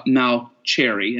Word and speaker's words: Mauceri: John Malcheri, and now I say it right, the French, the Italian way of saying it.